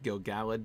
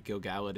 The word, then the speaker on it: Gil-galad: There's Gil-galad, the High King, whose name should really be pronounced Gil-galad. Don't let me corrupt you, Maggie. Gil-galad is how it's pronounced. It's one of those names I've been pronouncing wrong since I was like 8.